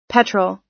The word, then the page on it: petrol: American English